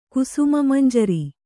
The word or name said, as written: ಕುಸುಮಮಂಜರಿ